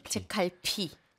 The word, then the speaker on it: 책갈피: 책갈피 아, 발음 어떠세요? – 좀 어려워요. – 책갈피. – 책갈피. – 그죠. – 계속 지금까지도 제칼비로 읽을 때도 있어서.